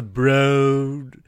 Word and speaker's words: Broad: It's spelled Broad. But I was corrected actually it's the Broad when we went in there, and some of the stuff is really cool.